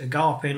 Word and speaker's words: agape: So the context that you've given us, is around this agape love. This is that the right pronunciation? – Agape. – From agape. Yeah.